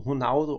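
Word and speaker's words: Ronaldo: til at lære Lars Jacobsen, hvordan man udtalte navnene, ja, Hivaudo, Honaudo og så videre.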